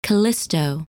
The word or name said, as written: Callisto